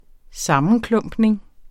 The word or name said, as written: sammenklumpning